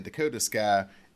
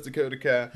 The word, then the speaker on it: Dakota Kai: She mentions EO Sky and Dakota Sky, and Bayley's like, yeah, I know I said her name wrong. I know it's Dakota Kai.